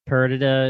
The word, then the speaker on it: Perdita: Now that we know that Perdita, is that how you say it? – Yeah.